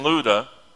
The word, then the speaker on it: Lydda: Now it says, it's spelled Lydda, and I've always had trouble with that word, but I finally am getting it down. It's Lydda. Say it with me.